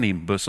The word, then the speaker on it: nimbus: Och hur uttalas nimbus får vi också reda på. Nim, som i Nimnim, om ni känner henne här, så var med i kören. Nimbus.